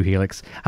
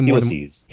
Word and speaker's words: helices: We've got two Helix. Okay, what's the plural? Heli? Helixi? How do you say Helices. – Helices. Okay.